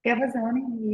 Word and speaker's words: Gavazzoni: Am I saying that right? Gavazzoni. – Gavazzoni, you said just right.